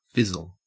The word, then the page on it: fizzle: Australian English